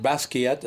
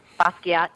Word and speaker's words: Basquiat: Is it Basquiat? Is that how you say his name? – Basquiat, yes. – Basquiat. I'm the Basquiat of teasing. – That's how I pronounce it, Basquiat.